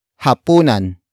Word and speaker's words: hapunan: It is an object marker that introduces what you are supposed to cook, and that is hapunan.